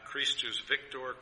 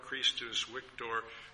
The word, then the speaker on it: Christus Victor: if you're pronouncing it as we would in Latin, Christus Victor, Christus Victor.